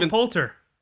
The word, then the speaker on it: Will Poulter: Will Poulter. Is that how you say his name? – Yeah, he's leaving, Will Poulter. – Poulter, that's how it is.